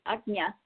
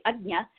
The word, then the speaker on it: Ajna: Because the third eye chakra, the Ajna, or the Ajna, it's spelled A-J-N-A, but it makes that funny little ny sound in there when you say it in Sanskrit.